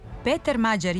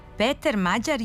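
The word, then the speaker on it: Péter Magyar: Ali u elektronskim medijima prezime mađarskog premijera akcentuje se drugačije, onako kako smo čuli na početku emisije. – Peter Mađar je Mađar.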